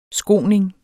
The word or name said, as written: skoning